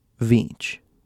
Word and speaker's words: Veach: Veach. Spelled like beach, but with a V. V as in Victor.